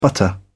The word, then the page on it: butter: British English